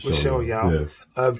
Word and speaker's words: Michelle Yeoh: Michelle Yeoh.